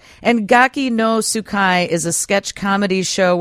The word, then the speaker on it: Gaki no Tsukai: Like, I know there's a show called Gaki no Tsukai? I don't even know how to say it. – Yeah, that was right. You were close. Gaki no Tsukai, and I do.